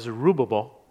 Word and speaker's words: Zerubbabel: How many of you remember when we did Nehemiah, Zerubbabel? This is the correct way to say it.